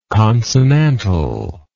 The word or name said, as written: consonantally